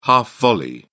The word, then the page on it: half volley: British English